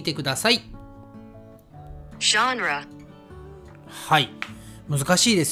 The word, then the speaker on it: genre: えー、ジャンラっていうのかな。はい。発音が難しいので、日本人は大体ジャンルと言っていますね。